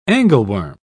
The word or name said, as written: angleworm